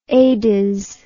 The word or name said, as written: adiz